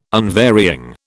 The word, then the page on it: unvarying: British English